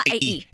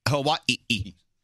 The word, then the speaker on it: Hawaii: Hawaii. – It's Hawaii. – So it's got four syllables and not three.